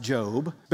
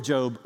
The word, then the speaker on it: Job: So if you're new to church or you're new to the Bible, this is the book that looks like it is Job, but it's actually pronounced Job. But here's the story of Job really quickly.